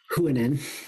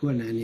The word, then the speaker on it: Huanan: Huanan. – Huanan, is that how you say it? Yeah. – Huanan, yes.